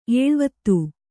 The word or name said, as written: ಎಳೆವಾತು